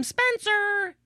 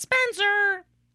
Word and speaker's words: Spencer: That's my name, Spencer. You gotta say it like that, Spencer.